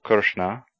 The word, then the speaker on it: Krishna: In India today, the R vowel is often pronounced RI, ri, as in the name Krishna or Krishna.